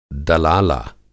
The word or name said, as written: दलाला